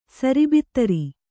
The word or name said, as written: ಸರಿಬಿತ್ತರಿ